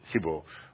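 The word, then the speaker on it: Sibiu: Right in the heart of Transylvania in, I don't know how to pronounce Sibu. – It. Sibu.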